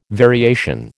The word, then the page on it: variation: American English